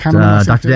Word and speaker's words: Kahneman: I also made the connection to Thinking Fast and Slow, that Daniel Kahneman, I'm probably mispronouncing his surname. – Sorry, no, that's Daniel, right. Kahneman, Dr. Daniel Kahneman and Dr. Amos Tversky, right, who's a collaborator on that incredible body of work.